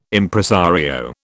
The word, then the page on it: Impresario: British English